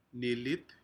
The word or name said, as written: nilit